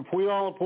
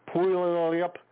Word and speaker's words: Puyallup: Puyallup, Puyallup. Pull your lips.